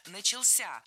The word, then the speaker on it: начался: 14:00 начался огромный парад вдоль набережной. – Начался неверно, правильно, начался. Ударение в этом слове падает на последний слог, поближе к возвратной части «ся».